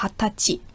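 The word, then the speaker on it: Hatachi (はたち): Und wichtig ist auch, dass es gibt eine Ausnahme von der Aussprache her, und zwar 20 Jahre alt, sagt man Hatachi.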